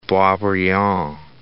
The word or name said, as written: Boisbriand